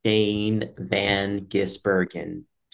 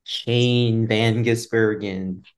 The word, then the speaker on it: Shane Van Gisbergen: Ladies and gents, this is how you properly pronounce it. Say it with us. And it's like, you know, being in kindergarten all over again when you're learning to read. – Shane Van Gisbergen. Shane Van Gisbergen. So it's not that hard.